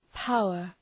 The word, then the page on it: power: ‘paʋər